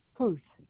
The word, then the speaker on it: Huth: My first name is Thomas, and my wife is Kimberly, and the last name is Huth, and it's spelled H-U-T-H.